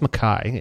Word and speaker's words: Mekhi: Um, weirdly, I'm gonna I'm gonna start it with wrestling, um, and I'll probably butcher this, but uh, it's I think it's Mekhi. It's M E K H I.